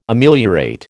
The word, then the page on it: Ameliorate: American English